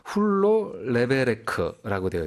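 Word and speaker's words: Hulló levelek: 그래서 이게 어떤 발음인가 한번 찾아보니까요. – 네네네. – 훌로 레베레크라고 되어 있더라고요. – 훌로 레벨릭. – 레베레크. 훌로 레베레크인데요.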